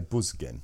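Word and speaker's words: Büsgen: Umlaut, so I would say Busgen.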